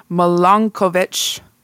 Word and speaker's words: Milankovitch: This is often referred to as the, I'm going to say this wrong because it's probably like a German or a Russian name, Milankovitch cycles. Maybe. – I think you nailed it.